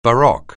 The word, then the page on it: baroque: anglais